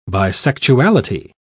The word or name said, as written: bisexuality